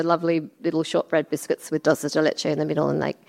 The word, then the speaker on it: dulce de leche: Dolce leche? Is that how you say it? – Dulce de leche. – Dulce de leche.